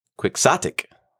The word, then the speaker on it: quixotic: Quixotic. Not exotic. Quixotic. – Can you spell it, please? – Quixotic. It is a great Scrabble word. Make sure you get that Q and X on the triple letter with the double word, or yeah, double word score. You'll do great. – We're pronouncing the X in that.